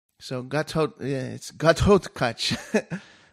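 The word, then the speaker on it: Ghatotkach: So it's Gathotkach. I used to say Gathotkach, but you got to say it right. It's the wrong. It's Gathotkach.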